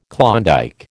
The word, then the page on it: Klondike: American English